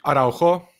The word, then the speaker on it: Araujo: Så han har bare kontroll på men Arujo på eh, Barcelona. Det er vel rett uttalelse av navnet hans? Araujo. Araujo, sorry.